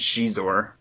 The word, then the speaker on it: Xizor: And there's this criminal organization called Black Sun headed by Prince Xizor.